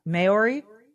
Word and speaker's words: Maori: So he's from New Zealand, and I cannot pronounce the name of his tribe, Maori. It's spelled M-A-O-R-I. – Maori. – Maori? Yep. – Correct.